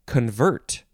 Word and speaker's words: convert: The next word is convert. Second syllable is emphasized.